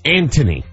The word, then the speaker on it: Anthony: It's Anthony. – So no H. There's an H, but there is no H. – The correct British pronunciation is Anthony.